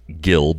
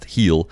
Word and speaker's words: Gil: Yes, it looks like Gil, but it is pronounced Heel.